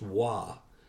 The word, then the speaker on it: Roy: His father's name was Nicholas King, whose father's name was Pierre Roy, R-O-Y, and it's pronounced Wah instead of Roy.